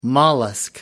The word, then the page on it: mollusk: British English